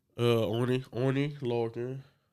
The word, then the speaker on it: Oney Lorcan: El Legado del Fantasma is taking on Breezango and Oney Lorcan and Danny Burch. Correct? Am I saying his name right? – Oney Lorcan. And Danny Burch. – Okay, I was. Cool. I thought I was saying it wrong.